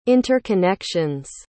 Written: interconnections

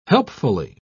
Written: helpfully

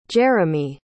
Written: jerimy

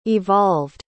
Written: evolved

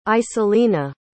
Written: isolina

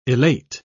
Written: elate

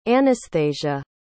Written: Anasthasia

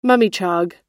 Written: mummichog

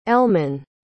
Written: Elmen

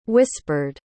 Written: whispered

